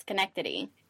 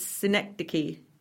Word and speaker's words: Synecdoche: I must be saying it wrong because I say it's Schenectady. – No, that's actually the place in New York. – That's the town, isn't it? – And it's not. It's supposed to sound like that. It's Synecdoche.